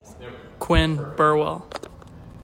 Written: QUINN BURWELL

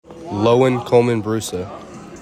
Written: LOWEN COLMAN-BRUSA

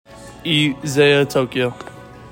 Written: EZAYA TOKIO

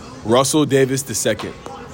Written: RUSSELL DAVIS II

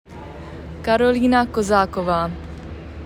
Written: KAROLINA KOZAKOVA

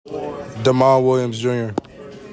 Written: DEMOND WILLIAMS JR

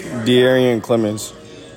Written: D'ARYHUAN CLEMONS

